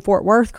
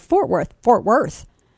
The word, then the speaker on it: Fort Worth: The worth gets the emphasis. The stress is on the worth, as though worth is its, as if it's a, you know, a two syllable word, and worth is what gets the stress.